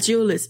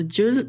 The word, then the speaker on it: Jules: É Jules, isso. Jules Jules Verne.